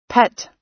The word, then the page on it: pet: American English